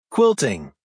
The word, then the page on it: quilting: American English